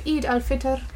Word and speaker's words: Eid al-Fitr: On Thursday is the festival of Eid al-Fitr.